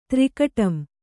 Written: ತ್ರಿಕಟಂ

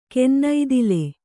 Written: ಕನ್ನೈದಿಲು

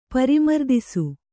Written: ಪರಿಮರ್ದಿಸು

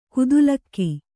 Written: ಖತಿಲಕ

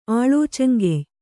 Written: ಆಳೋಚಂಗೆಯ್

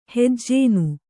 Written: ಹೆಜ್ಜೇನ್

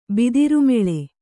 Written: ಬಿದಿರುಮೆಳೆ

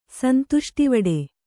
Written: ಸಂತುಷ್ಟಿವಡೆ

